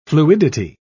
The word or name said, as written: fluidity